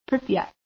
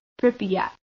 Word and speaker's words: Pripyat: Um, and essentially, where we're looking at is Ukraine, in particular, a city called Pripyat. Am I saying that correctly? – Say it again. – I believe it's Pripyat, like Pripyat.